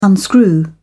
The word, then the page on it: unscrew: British English